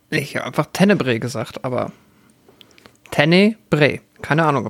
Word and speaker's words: Tenebre: wenn Deutsche den Filmtitel aussprechen, klingt das immer irgendwie falsch. Ähm, vielleicht ist es aber auch, wenn alle den aussprechen, irgendwie falsch. Es ist äh, Tenebre.